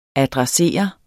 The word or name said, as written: adressere